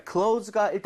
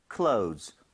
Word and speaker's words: clothes: 자, clothes가 일단 옷인 것도 알아야 되지만 얘는 사실 패턴을 알아야 되거든요.